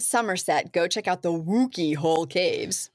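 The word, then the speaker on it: Wookey Hole: I don't know how to say it. Wookiee. Hole caves. – Sounds right to me.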